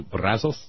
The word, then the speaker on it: Brazos: because I would have said Brazos. The Spanish pronunciation would be something close to Brazos.